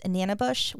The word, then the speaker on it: Manabus: It's spelled Manabus, but can be pronounced Nanabush, which I guess there's all different ways to say it because there were different tribes that had different ways of saying it.